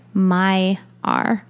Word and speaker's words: Maiar: And this is spelled M-A-I-A-R. And I have a note that it is pronounced, oh, I'm sorry, Mayer.